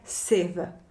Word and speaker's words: sieve: No, e, to síto v angličtině je siv. Jo? Si eve se to píše jako ošklivě, si eve a čte se to jednoduše siv. – Jo? Siv. – Siv. – Takhle, krátce. siv, krátce, siv.